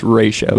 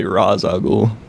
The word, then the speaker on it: Ra's al Ghul: It's not Raz, it's Raish. Christopher Nolan said it wrong. – Yeah. – It's Raish al Ghul.